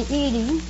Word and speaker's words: Edie: Oh, do they call you Edith or Edith? – They usually call me Edie. – Edie? – I'd like to have you call me Edie, if you don't mind.